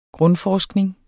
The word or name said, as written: grundforskning